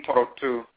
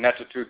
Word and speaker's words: Ntorotu: We talk about the nature and the function of the Abosom, the Orisha, the Vodou, the Ntoru and Ntorotu, sometimes mispronounced Neturu and Netutu,